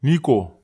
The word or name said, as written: niko